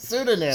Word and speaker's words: pseudonym: Six criminals, each with each strangers to one another, with the Sweetedums, Mr. White. Why? How do you say it? Sweetemans? – Pseudonym. – Pseudonym? Sweetenim. The pseudonyms.